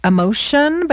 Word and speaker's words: Emocean: That's called Emotion, but it's spelled E-M-O-C-E-A-N.